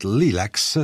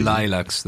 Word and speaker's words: Lilacs: Ok, in questo disco che ho tra le mani, che porta il titolo di Midnight Lilacs, si pronuncia così. – Lilacs, dovrebbe.